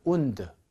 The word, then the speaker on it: wound: 자, 상처 입히다, 부상당하게 하다는 wound, wounded, wounded 이렇게 읽습니다.